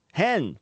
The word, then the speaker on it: hen: A new pronoun, hen, pronounced like the bird in English, was added to the online version of the country's national encyclopedia.